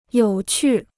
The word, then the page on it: 有趣: yǒu qù